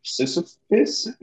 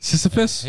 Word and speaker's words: Sisyphus: But, probably King Sisyphus. That's how you say it. – Sisyphus.